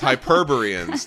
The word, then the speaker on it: Hyperboreans: Yes, Hyperboreans.